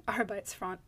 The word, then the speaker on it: Arbeitsfront: I said that wrong. Arbeitsfront, not fort.